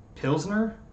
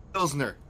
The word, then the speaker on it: Pilsner: Like, how do you say it? – Pinsters? – Is that what it is? Pinsters? It's a type of beer. – Pilsner? – Pilsner.